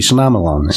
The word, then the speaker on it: Shyamalan: Night Shyamalan. – The movie was horrible. – I'm going to not butcher his name. – M. Night Shyamalan. – It's easy. Shyamalan.